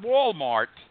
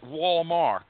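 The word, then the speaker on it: Wallmark: Not Walmart, but Walmark.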